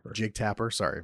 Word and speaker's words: Jake Tapper: because the only like real positive and Jack Tapper, I believe is how you pronounce his last name. – Jake Tapper.